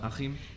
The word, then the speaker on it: Achim: It is very German, A-C-H-I-M, Achim. There's only one English word, which is ich. – Okay. – Where the Americans say ch.